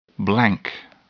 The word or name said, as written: blank